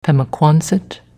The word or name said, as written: Pemaquansett